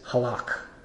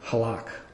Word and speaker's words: halak: I'm not going to pronounce it right, but my best effort is halak, in English, H-A-L-A-K.